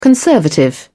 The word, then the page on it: conservative: British English